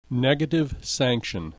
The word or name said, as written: negative sanction